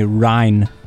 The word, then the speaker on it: Ryne: It's like the Rhine River or Ryan Sandberg of the Cubs, so it's one syllable. – I really don't get it. – Say it with me, Rhine. – Oh! – So it's spelled R-Y-N-E.